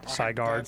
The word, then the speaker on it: SIGARD: The surveillance system, dubbed SIGARD, is that how you say it? SIGARD. – That's how I pronounce it. – S-I-G-A-R-D, has been installed in Dutch city centers, government offices, and prisons.